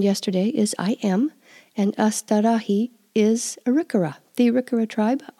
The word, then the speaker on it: astârâhi: A-sta-ra-hi. Or hit. Sometimes when that I ends a word, it sounds more like E, like a long I than a short I. We hear it both ways, astarahi or astarahit. So don't get too worried about that. People pronounce them a little bit different ways.